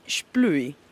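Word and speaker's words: splüi: In Valle Bavona, dicevi, esistono, adesso lo dico io, sicuramente sbaglio la pronuncia, gli Splui. – Sì, assolutamente giusto.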